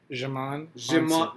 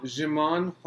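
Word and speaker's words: Djimon: So you don't say D. – No. Jimon. – Jimon Huansu. Yeah, so there's the D is silent.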